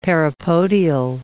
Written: parapodial

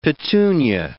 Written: petunia

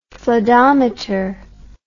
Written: floodometer

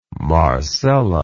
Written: marcella

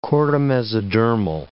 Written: chordamesodermal

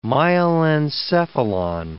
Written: myelencephalon

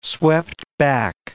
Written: swept-back

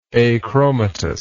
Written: achromatous

